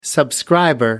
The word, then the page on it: subscriber: American English